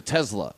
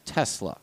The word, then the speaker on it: Tesla: It's a Tesla. No, there's no fucking Z in that word. It's an S. Tesla.